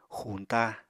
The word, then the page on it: xuñta: Apatani